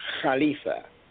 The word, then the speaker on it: Khalifa: It's a little hard on the throat, but it's pronounced Khalifa.